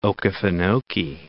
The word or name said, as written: Okefenokee